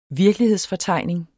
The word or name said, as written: virkelighedsfortegning